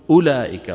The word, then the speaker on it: أولئك: أولئك جزاؤهم، طبعا أولئك شايفين فيها واو، هذه الواو لا تلفظ، قلنا بالأمس أن أولي وأولئك هذه الكلمات فيها واوات كتبت، كانت العرب تكتبها كذلك، لكن ما تلفظ هذه الواو. شو بتقول؟ أولئك، ولا تقول أولئك، هذه الواو لا تقرأ أبدا.